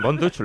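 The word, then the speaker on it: Von Dutch: Así que, Bonduch, ¿lo he pronunciado bien? – Sí, muy bien.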